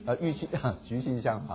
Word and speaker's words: 郁金香: TULIP， TULIP 啊，翻成中文就是那个曲金香 TULIP 啊，郁金香啊，郁金香啊 OK，幸好改正一下这个发音。